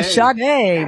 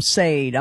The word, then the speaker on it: Sade: Yeah, Sade, man. Yeah, exactly. Yeah, I said it wrong on live on radio. I called him Sade. I'm like, oh, I never lived that one down. So, yeah, Sade.